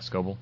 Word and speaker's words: Scoble: Is that how you say his name? – Yeah, Scoble. Mm-hmm.